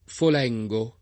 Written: Folengo

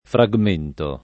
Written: fragmento

